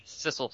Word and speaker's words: Sissel: Sissel. So it rhymes with missile.